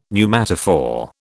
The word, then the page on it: pneumatophore: British English